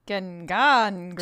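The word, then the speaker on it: gengångare: Ghoul in Swedish means Gengangare? – Gengangre.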